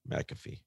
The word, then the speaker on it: McAfee: And I said his name right this time. – Yeah, McAfee.